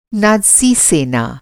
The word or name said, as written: नात्सी